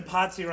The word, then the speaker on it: Potsy: There's a reason Potsy rhymes with Nazi.